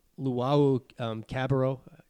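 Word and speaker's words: Luwawu-Cabarrot: He had one on Luau Cabarro. Did I butcher that? – Perfect.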